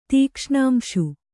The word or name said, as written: ತೀಕ್ಷ್ಣಾಂಶು